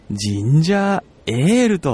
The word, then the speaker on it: 神社声援: 神社声援、声で応援すると書いてありまして、神社声援、神社エールとこのように読むそうなんですね。